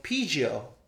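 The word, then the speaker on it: Pijiu (啤酒): Pijiu is how you say beer. So Pijiu. Pijiu. That's beer in Chinese.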